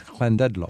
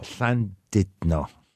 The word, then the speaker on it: Llandudno: So this is Llandudno. – Llandudno.